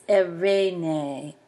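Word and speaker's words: Irene (Εἰρήνη): It's Irene, Irene. Note that the Yoda Epsilon that begin the word and the two Adas in the middle and at the end are all pronounced exactly the same, A.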